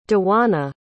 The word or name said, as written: djuana